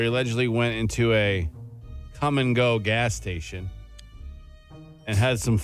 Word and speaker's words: Kum & Go: Well, Iowa man arrested for having self-fun at a come-and-go gas station. – If you've ever been to the United States and been to a come-and-go, it's spelled differently. – K-U-M.